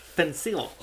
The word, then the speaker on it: Fencl: So pretty much that morning, Clara Ward, his wife, and the maid, Lillian Fenkel. It's a weird way to spell the name. F-E-N-C-L. – It is. – Fenkel, I'm assuming. – Yeah. – Lillian Fenkel? It's pronounced Fencil.